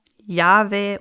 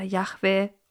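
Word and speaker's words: Jahwe: Die heutige Sprachforschung geht davon aus, dass Mose den Namen Yahweh mit der Aussprache Yahweh oder Yahweh offenbart wurde.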